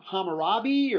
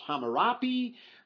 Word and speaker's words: Hammurabi: About 200 to 300 years before Moses, there was a king over in Babylon by the name of Hammurabi, or sometimes you find it spelled or pronounced Hammurapi.